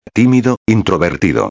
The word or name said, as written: timido introvertido